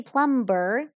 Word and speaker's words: plumber: First of all, have you noticed that I don't say plumber? Plumber. So the B is silent.